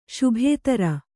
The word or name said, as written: ಶುಭೇದಾರು